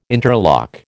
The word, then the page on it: interlock: American English